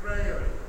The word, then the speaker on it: Freire: I always want to say free air, and I know it's not right. And I asked somebody the other day, how do you pronounce that correctly? Because I'm in Brazil. Freire.